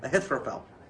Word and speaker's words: Ahithophel: But verse 12 is what is important, and while Absalom was offering sacrifices, he sent for Ahithophel. Am I saying it right? – Ahithophel.